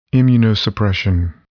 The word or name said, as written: immunosuppression